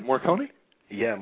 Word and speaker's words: Morricone: Ennio Morricone. – How do we pronounce his last name? Morricone? – Yeah, Morricone, yeah.